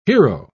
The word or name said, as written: hero